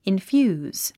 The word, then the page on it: infuse: infjúːz; インフューズ